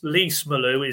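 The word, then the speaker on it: Lees-Melou: So there's some Lease Malou is lease as in car lease.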